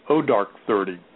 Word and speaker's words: Zero Dark Thirty: Also, down here in South Florida, we're not getting some of the Oscar movies yet, like Zero Dark Thirty. Or actually pronounced O Dark 30.